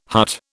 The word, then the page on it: hut: British English